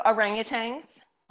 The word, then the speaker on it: orangutan: So orangutans. – Orangutans, or orangutan, I believe it's supposed to be pronounced.